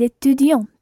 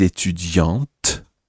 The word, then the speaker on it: étudiante: Je suis étudiante. – Très bien. Now, Anna says, je suis étudiante, with a T sound at the end.